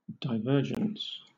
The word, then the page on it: divergence: British English